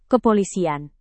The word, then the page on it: kepolisian: indonesian